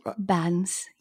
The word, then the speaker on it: Bans: Bans, yeah.